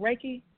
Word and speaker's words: Reiki: Is it Reiki Master? Am I saying that right? – You got it. That's it.